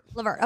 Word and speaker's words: LeVert: LeVert. Okay, I did say it right. – Like Gerald LeVert.